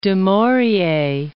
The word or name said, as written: du Maurier